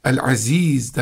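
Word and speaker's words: El-Aziz (العزيز): El-Aziz derken o A'nın çeddesi var. Yani şedid bir şekilde ağzı doldurması var. Aziz derken o ayında oturmak gibi bir harf telaffuzu var.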